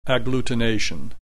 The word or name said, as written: agglutination